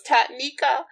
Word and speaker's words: Tanika: PEEP, it's, my name is difficult to pronounce on paper. It's only three syllables, Tanika. But of course, it has a funky spelling, especially for Americans to pronounce.